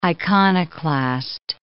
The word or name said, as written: iconoclast